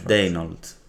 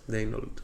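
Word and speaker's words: Daigneault: Ο ένα ήταν ο Μαρκ Ντενιόλτ, δεν ξέρω πώ προφέρεται. – Ναι, Ντέινολτ. – Ντέινολτ, οκ. Most improved.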